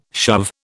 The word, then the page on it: shove: British English